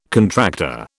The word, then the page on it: contractor: British English